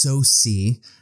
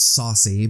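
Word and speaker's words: Sosie: And then, of course, our star is, and I believe it's pronounced Saucy, not Saucy, but Saucy Bacon.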